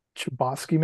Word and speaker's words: Chbosky: Chbosky, maybe? Isn't there no T in it? It's like Chbosky. – Yeah, has the correct pronunciation.